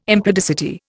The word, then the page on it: impudicity: Indian